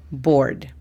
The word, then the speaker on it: bored: El segundo adjetivo es bored. Bored significa aburrido. La pronunciación fonética es B-O-R-D, bored. La tercera y última es brave. Brave significa valiente. La pronunciación fonética es B-R-E-I-V, brave.